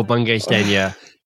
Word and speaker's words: Bungay Stanier: because Michael Bungay Stanier gets mispronounced more often than not.